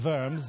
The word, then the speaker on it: Worms: When he was confronted in 1521 at the Diet of Worms, I have to say it like a German, Worms, the Diet of Worms.